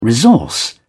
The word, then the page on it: resource: British English